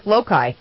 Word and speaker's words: Loki: Well, not mischief, Loki.